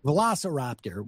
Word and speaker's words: Velociraptor: Velociraptor, which is a, thank you, which is a souped-up Ford Bronco, or $100,000 in cash.